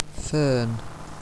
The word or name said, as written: fern